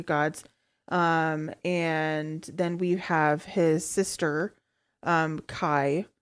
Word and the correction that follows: Ki: I knew I was going to say that wrong. Her name is Ki.